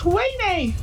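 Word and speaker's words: Ouaisné: We live next to a place that's spelt O-U-A-I-S-N-E, but it's pronounced Huayne.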